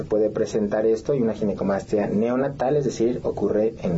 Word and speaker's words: ginecomastia: Pues muchas gracias, y sí, definitivamente creo que es una, para empezar la palabra, le decía, ¿cómo se pronuncia? Ginecomastia. – Sí, exacto. Ginecomastia.